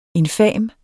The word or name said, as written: infam